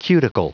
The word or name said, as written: cuticle